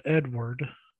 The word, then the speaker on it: Eadweard: It's called Edward. Uh, but it's spelled E a D W E A R D.